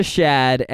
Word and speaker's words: Shad: Boyfriend's name is Shad. – Like Shad? – Like Chad, but with an S. – Is that... That's how it's spelled? – Yes, that is S-H-A-D, Shad.